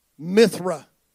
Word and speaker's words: Mithra: The 25th of December, winter solstice, Roman holiday, to Mirtha, or I said that wrong, Mithra, the sun god, 25th of December.